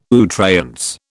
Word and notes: à outrance: British English

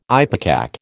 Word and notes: ipecac: American English

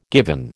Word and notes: given: American English

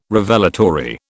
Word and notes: Revelatory: British English

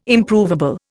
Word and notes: Improvable: Indian

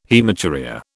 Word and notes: haematuria: British English

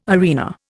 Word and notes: arena: Indian

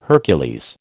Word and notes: Hercules: American English

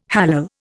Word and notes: hallal: Indian

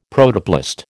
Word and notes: Protoplast: American English

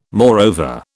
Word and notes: moreover: British English

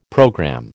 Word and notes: programme: American English

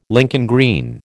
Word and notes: Lincoln green: American English

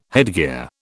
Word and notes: headgear: British English

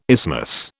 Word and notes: isthmus: British English

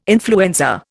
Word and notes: influenza: Indian

